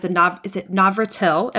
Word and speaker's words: Navratil: And that is Navratil? Am I pronouncing that correctly? – From what I know, yes.